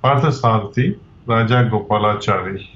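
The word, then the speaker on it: Parthasarathi Rajagopalachari: Parthasarathi Raja Gopalachari.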